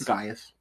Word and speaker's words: Gaius: It's G-A-I-U-S. Mm-hmm. I don't know if it's, is it pronounced Gaius? – Gaius? – Gaius? – Gaius. – Let's be real. Julius Caesar, it's Gaius.